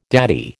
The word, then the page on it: daddy: American English